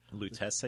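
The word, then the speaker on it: Lutece: And I just have to say, I didn't, even though, you know, the, I made the connections between Rosalind and Robert Lutes. I don't know if I'm saying it right. – Lutes, I think it was. – Lutes.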